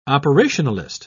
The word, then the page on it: operationalist: British English